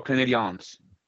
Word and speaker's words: Canadiens: The Montreal Canadiens spell it with an E. It is the French pronunciation.